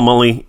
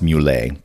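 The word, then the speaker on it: Mullie: And of course, Paul Mully is the other teleplay writer for this episode. – It's actually pronounced Mule, but you know.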